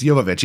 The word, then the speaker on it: Jovovich: Is it Jovovich or Jovovich? – It's Jovovich, yeah. No, the J, from my, when we looked it up, I remember that the J was, you know, it was a silent J.